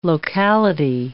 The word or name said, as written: locality